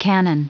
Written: cannon

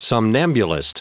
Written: somnambulist

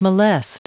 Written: molest

